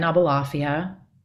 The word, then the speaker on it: Aboulafia: At Lauren Aboulafia.